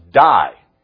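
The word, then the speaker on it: die (δεῖ): The actual Greek word is pronounced in English, die. It's D-E-I, but it's pronounced die.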